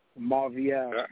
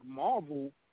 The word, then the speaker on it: Marvielle: Oh, you pronounce it Marvielle? – Marvielle. – I think I saw it and I read it as a lot of people be like Marvel.